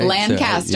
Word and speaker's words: Lancaster: It's not Lancaster, right? – Lancaster, so, right. Yeah. Lancaster. – I just want to make sure you caught that. – Lancaster, South Carolina. Lancaster. – You have to say it right.